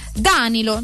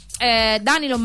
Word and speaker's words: Danilo: Su Danilo e Danilo non avrei avuto dubbi neanch'io. E invece lui, niente, metteva l'accento sulla prima sillaba. Comunque... Danilo Mainardi che ha parlato negli anni,